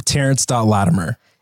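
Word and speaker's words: Terrence Latimer: It's just my name, Terrence.Latimer.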